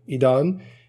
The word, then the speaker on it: Edon: But I'm the only Edan I've met, if you can say I've met myself, who spells it the way I spell it, E-D-O-N. All but one of the Edans I know, I-D-A-N, one E-D-A-N, that's a bit of a weird one.